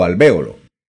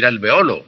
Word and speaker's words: alvéolo: En español existen palabras que tienen una doble pronunciación, como las que usted cita. Se puede decir médula o se puede decir medula. Se puede decir alvéolo o se puede decir alveolo, el lugar donde se alojan los dientes.